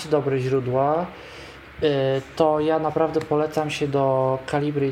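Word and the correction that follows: Calibre: i tak swoją drogą zapisać, do Kaliber właściwie, bo to tak się powinno wymawiać, bo to brytyjska pisownia.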